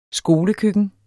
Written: skolekøkken

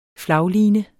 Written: flagline

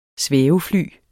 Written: svævefly

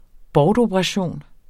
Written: bortoperation

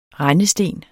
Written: rendesten

